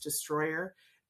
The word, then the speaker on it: Destroller: It's spelled Destroller, but it's pronounced Destroyer.